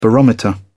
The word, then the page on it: barometer: British English